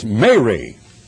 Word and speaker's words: Mary: There are 3 ways to pronounce 3 different words, and one is M-E-R-R-Y, and that is Mary. You're merry, you're happy, you're very merry. But this is Mary. It is not Mary, but Mary.